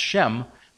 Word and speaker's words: Shem: So we see it with an H in there and it's pronounced Shem, but they were Semites, S-E-M.